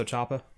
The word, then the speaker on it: Ciampa: How do you say his name? Ciampa? – Tommaso Ciampa.